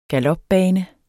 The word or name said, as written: GALOPBANE